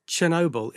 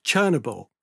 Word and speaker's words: Chernobyl: But by the way, I've discovered it's not pronounced Chernobyl, it's Chernobyl. So from now on, really? – Yeah, it's Chernobyl.